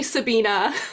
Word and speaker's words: Sabina: Am I pronouncing that right? – It's actually Sabina.